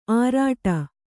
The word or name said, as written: ಆರಾಟ